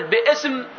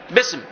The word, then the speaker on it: بسم: هل ستقول باسم أو تقول بسم؟ بسم صح، تقول بسم.